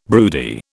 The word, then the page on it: broody: British English